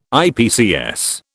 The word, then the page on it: IPCS: British English